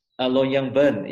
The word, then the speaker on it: Longyearbyen: Longyearbyen, yeah.